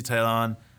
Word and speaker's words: Taillon: Did I say it right this time? Yeah, I think that's right. All right. – All right. Um, we get to see Taillon, Montgomery, and it will be really fun to see uh, Cole pitch in between Montgomery and Taillon.